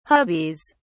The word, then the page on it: hubbies: British English